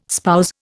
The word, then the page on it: spouse: Indian